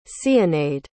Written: zénaïde

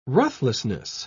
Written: ruthlessness

